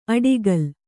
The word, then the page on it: ಅಡಿಗಲ್: Kannada